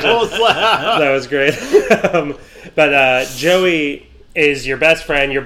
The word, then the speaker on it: Jowy: Joey or Jowey. I don't know how to pronounce it. – I said J-O-W-Y.